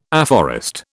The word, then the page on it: afforest: British English